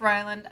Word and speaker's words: Ryland: This one is about a radar technician named Rayland. Or Ryland? – Rylan. – How did they pronounce it? Ryland? – It's Ryland. I didn't like the name, but if your name's Ryland, whatever.